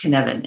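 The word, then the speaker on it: Cynefin: Yeah, a Cnefin model, Cnefin, but it's Welsh, and it's pronounced Cnefin, and I'm not a Welsh expert.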